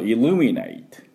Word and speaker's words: illuminate: でこのイルミネイト、これも発音をやっていこうと思います。イルミネイト。はい。でこれはですね、イルのところにアクセントがあります。 L ですね。 I-L-L-U-M-I-N-A-T-E。で後半の N-A-T はサイレント E になってますから、ネイトと発音します。